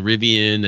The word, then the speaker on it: Rivian: And behind Rivian is the number one. I think that's it. That's how you pronounce that car company, right? – It is. Rivian.